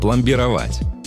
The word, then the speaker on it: пломбировать: Одно из них – пломбировать. Да, ударение в нем ставится именно так и никак иначе. Только такой вариант разрешает словари.